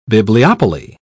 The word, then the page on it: bibliopoly: British English